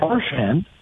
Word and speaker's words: Marchand: So Brad Marchand has told the Bruins he wants his name pronounced Marchand, not Marchand.